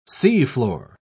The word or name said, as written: seafloor